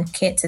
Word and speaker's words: Kits: You can find me on Twitter at FinTech Kits. That's FinTech, the way you would usually spell it, and then Kits is K-I-T-S.